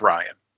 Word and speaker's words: Rian: And if you haven't, like, if you're not familiar with, like, Rian Johnson, you need to go back and It's pronounced Ryan. – Okay, Rian Johnson.